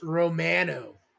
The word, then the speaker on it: Romano: You pronounced it wrong, Bo. It's Romano.